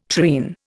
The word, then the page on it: treen: Indian